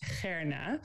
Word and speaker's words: Gerna: So for those who want to try, um, I would pronounce my name as Gerna, which is a little bit further down your throat.